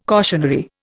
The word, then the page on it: cautionary: Indian